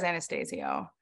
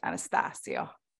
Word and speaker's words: Anastasio: Well, how would you say it if I wasn't in the room? You would make it more Stasio. – Italian. Yeah. That's probably the proper way to say it, but he says Anastasio, but his people, his legacy would probably prefer if it was Anastasio.